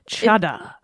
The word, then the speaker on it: Ceadda: Chada. – Chada? It's spelled Chada cheese. It's spelled C-E-A-D-D-A.